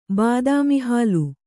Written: ಬಾದಾಮಿಹಾಲು

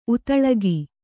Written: ಉತಳಗಿ

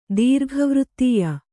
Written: ದೀರ್ಘವೃತ್ತೀಯ

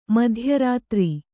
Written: ಮಧ್ಯರಾತ್ರಿ

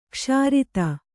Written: ಕ್ಷೀರೋದ

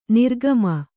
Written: ನಿರ್ಗಮ

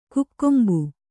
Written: ಕಕ್ಕಂಬು

